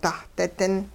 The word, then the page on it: dahdi-din: hupa